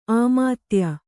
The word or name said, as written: ಆಮಾತ್ಯ